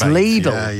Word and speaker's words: Lidl: It's Lidl.